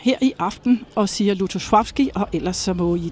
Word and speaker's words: Lutosławski: Ja, altså de fleste her på bagscenen siger faktisk Lutosławski, har jeg lagt mærke til. Men altså når jeg er i selskab med polakker, så siger jeg det i hvert fald ikke. Fordi der hedder det Lutosławski. – Der er den der lille streg over det andet L, som betyder, at man skal udtale det mere som V.